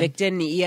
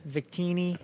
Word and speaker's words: Victini: Victini EX. – Victini.